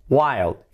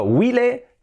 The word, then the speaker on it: while: La primera que he dicho que sería while, while, escrito while, la pronunciación, cuidado que es while, while, es casi como salvaje, wild, wild, pero sin D, while.